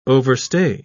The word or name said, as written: overstay